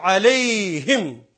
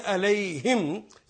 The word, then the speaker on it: علیہم: اب دیکھیے یہ کلمہ مغضوب علیہم, علا کا مطلب پر اور ہم ان سب پر, لیکن یا کی وجہ سے اس کو پرونانس کریں گے علیہم زیر کے ساتھ, تاکہ علیہم یا کے بعد زیر ہی آئے گی,